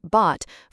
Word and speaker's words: Bawte: Bot, pronounced like bot, forces explanations like, "It's bot, as in we bought the domain name for 99 cents, but you spell it B-A-W-T-E."